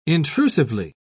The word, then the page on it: intrusively: British English